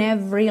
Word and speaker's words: every: Every, porque o E do meio dessa palavra não é pronunciada, every line.